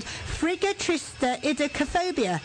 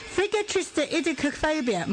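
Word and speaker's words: friggatriskaidekaphobia: Formally, Fear of Friday the 13th is called Frigatristeidacophobia. Oh, wait, I said that. Let me say it again. Frigatristeidacophobia.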